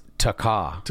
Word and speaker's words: Taakah: Thatcha. – It's pronounced Taakah.